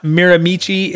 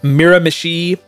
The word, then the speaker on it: Miramichi: It's not Miramichi, it's Miramichi, which makes sense had I thought about it for more than 10 seconds.